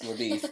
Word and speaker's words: LaBeouf: I can't believe it's pronounced LaBeouf.